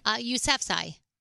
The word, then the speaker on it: Yousafzai: Yousafzai.